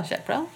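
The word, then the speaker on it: Jacques Brel: So Jack Brel. – Yeah, let's listen. We were trying to pronounce it before we got here, actually. – You're doing pretty well, Jack Brel.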